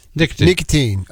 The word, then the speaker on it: Nikitin: Yeah, it's Vlad Nikitin. – Nikitin. Oh, yeah. Like what? Okay, got it. – Yeah, so if, like a nicotine patch.